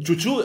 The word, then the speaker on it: Ciucciù: Ciucciù, intanto si pronuncia, è giusto come pronuncia? – Sì, è perfetto, perfetto, Ok.